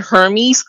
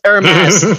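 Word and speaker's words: Hermès: So I just feel like the fact that people, oh my god, and when girls mispronounce it too, like they call it Hermes, no, it's Hermes.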